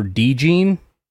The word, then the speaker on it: DeJean: Well, first of all, I could tick everybody off and say that apparently the way you say his name is Cooper DeJean, according to Dane Brugler.